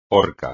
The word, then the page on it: horca: espagnol